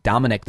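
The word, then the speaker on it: Dominick: But you can't get away with it when your name is Pete Dominic. There's no way. – Right. There's no way to mispronounce that. – Some Latino people will say Dominique, but that's okay, because that's how it's spelled.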